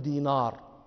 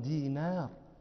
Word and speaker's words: دينار: النون رقيقة، النون حرف رقيق، لا نقول دينار، هذا نطق عجمي، نحن عرب نقول عبد الله بن دينار.